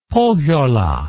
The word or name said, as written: pohjola